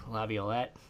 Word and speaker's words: Laviolette: Peter Laviolette.